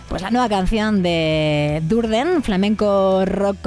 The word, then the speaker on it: Durden: No perdón que lo he dicho mal, el nombre viene de Tyler Durden, el personaje que interpreta Brad Pitt en el Club de la Lucha. Y Durden es el grupo que lidera Diego Losada, invitado en el último típico programa de Radio 3, el último quiere decir el pasado, el del pasado sábado.